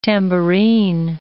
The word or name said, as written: tambourine